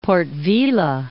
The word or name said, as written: Port-Vila